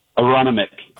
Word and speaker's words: Aronimink: Aronimink. – Aronimink, okay. – Yeah. It's kind of a silent N.